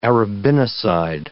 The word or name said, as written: arabinoside